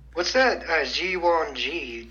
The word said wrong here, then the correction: Jiangshi: mentioned? I know I butchered that pronunciation. – So it's a Jiangshi.